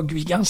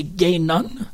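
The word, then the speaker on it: Guinan: Étrangement, la première prononciation du nom, c'est pas Guigan, c'est Gaynon. Donc, il fallait qu'on dise Gaynon.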